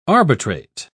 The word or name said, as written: arbitrate